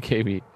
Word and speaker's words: Gaby: That's her real name, Gaby. When she writes it down, it looks like Gabby, but it's pronounced Gaby. – Well, Jim's always pronouncing it Gabby.